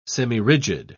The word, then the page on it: semirigid: British English